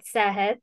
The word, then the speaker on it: Sehhat: So the organization is called Sehhat.